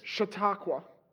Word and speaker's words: Chautauqua: Chautauqua.